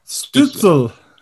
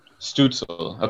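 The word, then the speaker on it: Stützle: How do we pronounce it? – Stutzle. – Stutzle. Okay.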